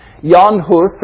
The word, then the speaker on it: Jan Hus: Well, John Huss, or Jan Hus, I don't speak Czech, so I'm not trying to make pretensions here, but that's what I've heard how it's pronounced. Jan Hus, or the English version, John Hus, was born in 1369.